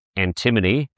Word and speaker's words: antimony: EV is an emerging critical minerals company strategically focused on supplying the North American market with high-grade antimony, that's how it's pronounced, from projects in Mexico and the US, driven by a processing-led strategy to achieve near-term cash flow.